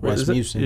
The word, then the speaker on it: Rasmussen: I think, how do you say, Rasmussen? – Rasmussen. – Yeah, Rasmussen.